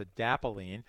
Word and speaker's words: adapalene: It's called adapalene. I pronounced it a little wrong earlier. It's adapalene.